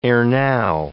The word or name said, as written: erenow